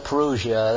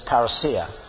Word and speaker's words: parousia: You hear people saying parousia, that's all right, you know, but the Greek pronunciation is parousia.